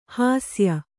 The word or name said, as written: ಹೇಸಿಯ